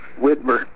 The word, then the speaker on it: Widmer: Ryan Widmer. Say your last name for me, Ryan. W-I-D-M-E-R.